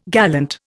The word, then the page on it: gallant: Indian